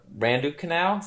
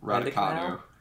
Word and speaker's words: Raducanu: Open, 18-year-old Emma Raducanu, is that how you pronounce that? – Raducanu.